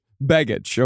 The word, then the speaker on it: Begich: Mark Begich. It is Begich. – Begich? – It's Begich. – Okay. – Just check. – Okay, it's Begich. Okay, so it's Nick Begich.